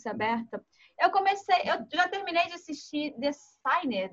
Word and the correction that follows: The Sinner: The Shiner, eu não sei como é que pronuncia esse negócio, não sei. Mas é, é, é policial. – The Sinner. Isso, me corrija, por favor.